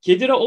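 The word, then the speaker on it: Khedira: Kedira mı? Çedira mı? Nasıl okunuyor? Tam bilemiyorum kendisini. – Yani Kedira diye yazılıyor. Yani Kedira evet.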